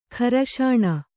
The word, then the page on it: ಘರ್ಷಣೆ: gharṣaṇe